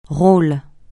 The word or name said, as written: rôle